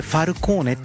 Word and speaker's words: FALCXNE: さあお送りしている曲はね、カナダトロントのアーティストなんですね、なんてアーティスト名読んでいいのかなってすごい FALCXNE、これでファルコーネって読むみたいなんですけどね、はい、ファルコーネで His Secrets でした。